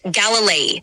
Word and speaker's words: Galilei: Galileo's surname is Galilei, pronounced Galilei.